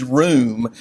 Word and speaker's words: rum: The original word in Hebrew is room. You would spell it in Hebrew, in English, R-U-M.